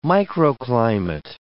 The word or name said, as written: microclimate